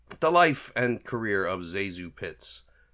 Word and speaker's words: ZaSu Pitts: But for this episode, we are going to look at the life and career of one ZaSu Pitts, I should say. That's the correct pronunciation.